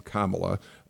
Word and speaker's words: Kamala: And to be fair and to give the Democrats a chance as well, Kamala Harris, and yes, that is how we pronounce her name, Kamala, Republicans out there, many of them have taken to mispronouncing the name. It feels intentional in some cases, in others, I accept the fact that maybe it's not, but it is Kamala Harris.